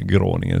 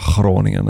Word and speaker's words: Groningen: Det är inte många som säger Groningen, utan där är det Groningen ändå.